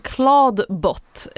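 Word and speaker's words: Clawdbot: Det är, för du snackar ju om Claude Bot, ett namn som egentligen gör sig lite bättre i text än i ljud, eftersom det uttalas likadant som Claude, alltså chatbotten från Anthropic, eller AI-modellen från Anthropic. Men det stavas alltså som Klo på engelska.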